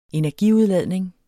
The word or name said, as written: energiudladning